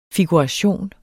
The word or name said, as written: figuration